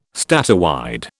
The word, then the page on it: statewide: British English